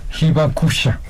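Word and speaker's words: Hibakusha (被爆者): Hibakusha.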